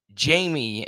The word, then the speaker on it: Jaime: It's spelled like that and pronounced like that, but Jamie is how you pronounce it, even though it's J-A-I-M-E with that R mixed in the French.